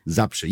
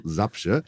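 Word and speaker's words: Zabrze: Ich würde Zabce sagen. – Sapsche. Ja, ich dachte, wenn es einer aussprechen kann, dann wahrscheinlich eher du als ich, also Sapsche.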